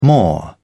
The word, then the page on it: more: British English